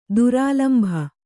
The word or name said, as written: ದುರಾಲಂಭ